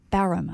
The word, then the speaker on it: Barham: So Barham, am I saying that right? – Barham, okay.